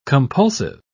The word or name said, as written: compulsive